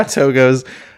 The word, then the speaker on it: Togo's: How's Togo's? Is that how you say it? – Yeah, Togo's.